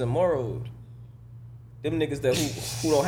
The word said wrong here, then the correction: Mero: I can't never pronounce their names. – It's Jesus and Mero, nigga.